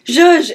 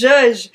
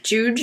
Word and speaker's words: zhuzh: I was like, zhuzh, it's zhuzh. But I said, pronounce like J-U-J-E. I don't know. I just wanted people to know what I meant by Z-H-U-Z-H because that's how you spell it.